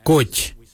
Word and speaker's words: goodj: Garli is spelled G-I-I-L-Y-I, garli. For the traditional stone axe, we say goj, goj. Goj is spelled G-O-O-D-J, goj.